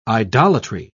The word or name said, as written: idolatry